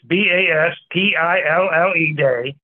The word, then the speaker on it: Bastille: That's B A S T I L L E Day. Okay, it's pronounced Bastille Day, July 14th, an annual holiday.